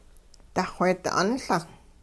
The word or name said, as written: duxweedi 'unLung